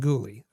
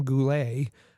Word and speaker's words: Guhle: it, it is uh, Kaiden Guhle. Uh, it's Kaiden Guhle. Uh, I know the owner likes to call him Goulet, uh, but no, it's uh, it's it's Kaiden Guhle.